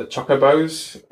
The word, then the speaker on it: Chocobo: So when you first get to the breeding farm for the chocobos, or however you're supposed to pronounce it, I always Chocobo.